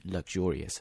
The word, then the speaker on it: luxurious: Luxury goods, 奢侈品 luxury 表示奢侈，那么奢侈的富丽堂皇的 luxurious, 注意它的发音 luxurious,